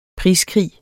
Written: priskrig

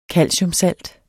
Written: calciumsalt

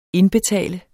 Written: indbetale

